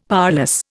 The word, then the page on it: powerless: Indian